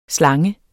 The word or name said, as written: slang